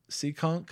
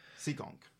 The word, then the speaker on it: Seekonk: Seekonk? – I can't even pronounce it right. Seekonk.